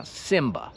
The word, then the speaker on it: Simba: A po angielsku się wymawia Simba.